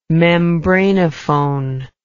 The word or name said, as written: membranophone